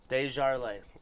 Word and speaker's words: Desjarlais: Give us the proper way to say your last name. – Desjarlais. – I would say Desjarlais because it's like a syllable. – It sounds bougie, but it's French, and we're the furthest thing from high class over here.